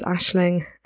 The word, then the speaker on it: Aisling: So if we had to do it all over again, for those that don't know, my name is Aisling and it's an Irish name, so it's spelt quite differently to how it looks and sounds, and it's all a bit of a mess.